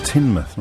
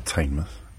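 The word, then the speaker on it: Tynemouth: Tynmouth, not Tainmouth. My apologies, I didn't know. Been there as well. Pronounced it wrong just clearing that up.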